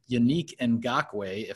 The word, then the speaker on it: Yannick Ngakoue: The Vikings made a trade during training camp, a very big headline type of trade, getting Jacksonville's Yannick Ngakoue, if I'm saying that correctly.